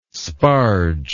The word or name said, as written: sparge